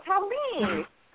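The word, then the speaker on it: Taline: I want to say Colleen. – It's Tallene. It's actually Tallene with a T. – Spell it for me. – It's T-A-L-I-N-E. – That's beautiful. Tallene.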